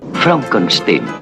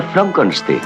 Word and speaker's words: Frankenstein: Doctor Frankenstein. Frankenstein. Se pronuncia Frankenstein.